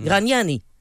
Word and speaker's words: Gragnani: Gragnani, quand il le prononce son nom, il le prononce à l'italienne. Mmh. Gragnani.